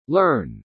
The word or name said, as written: learn